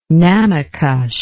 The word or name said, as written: namaycush